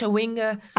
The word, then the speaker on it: Chawinga: Katoto and is it Chawinga? Is that how you pronounce it? – Chawinga.